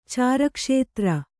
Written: ಚಾರಕ್ಷೇತ್ರ

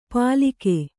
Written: ಪಾಲಿಕೆ

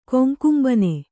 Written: ಕೊಂಕುಂಬನೆ

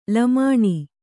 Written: ಲಮಾಣಿ

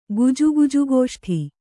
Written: ಗುಜುಗುಜುಗೋಷ್ಠಿ

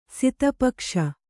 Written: ಸಿತಪಕ್ಷ

